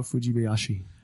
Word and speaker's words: Fujibayashi: Director for the game, Hidemaru Fujibayashi. Am I actually in there? – That wasn't that bad. – Fujibayashi? – How would you say that? Hidemaru Fujibayashi? – Hidemaru Fujibayashi.